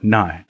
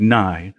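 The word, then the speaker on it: Ni: I'm like, Nye. They're like, what? I'm like, Nye. They're like, Mike? I'm like, Nye. I'm like, nine? No, Nye.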